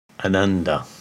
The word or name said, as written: Ananda